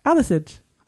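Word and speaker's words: Hathersage: Hather Sage is how she says it. – Oh. How should you say it? – Hather Sage.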